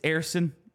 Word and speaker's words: Ersson: I know I need to say it right. It's Ersan. I just need to nail it down.